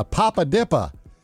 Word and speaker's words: Papa Dippa: It's called a Papa Dippa. And I said it correctly, D-I-P-P-A.